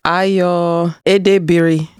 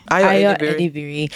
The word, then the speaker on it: Ayo Edebiri: It is Ayo Edebiri. – Ayo Edebiri.